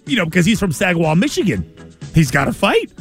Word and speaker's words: Saginaw: Saginaw.